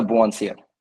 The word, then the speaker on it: Bouanssir: It's Hamza Bouanssir.